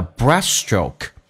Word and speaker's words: breaststroke: 长元音 e 那个 I 不要太饱满，因为主重元音是 E，同时 L 这个发音舌尖抬起来 O，再来一遍 O， freestyle and the breaststroke， breaststroke，那么指的是蛙泳，同时那个 T 是可以不用发音的，但是那个 S 要发两遍，你看一下 breaststroke， breaststroke，你不能说 breaststroke，叫 breaststroke。